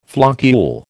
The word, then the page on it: floccule: American English